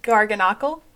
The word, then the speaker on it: Garganacl: The Garganical? – Uh, Garganacl? – Uh, say that again. – Garganacl? – Garganacl.